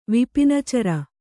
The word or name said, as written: ವಿಪಿನಚರ